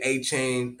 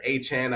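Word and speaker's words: Achane: We'll get A-Chain, or A-Chan, I think is how you pronounce it, back.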